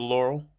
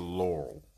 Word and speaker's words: Laurel: There's two syllables to Laurel.